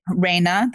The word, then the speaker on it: Reina: You mentioned your grandmother, which, you know, with Reina, that's how you pronounce it. It's actually Reina's, I always say Rina, but Reina is probably my daughter and I's favorite restaurants, like our happy place.